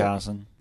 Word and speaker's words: Munchausen: Munchausen.